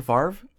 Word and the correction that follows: Favreau: John Favreau.